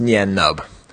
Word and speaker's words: Nien Nunb: So so I was viciously attacked for my belief that it's pronounced Nyan Nub.